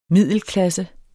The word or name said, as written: middelklasse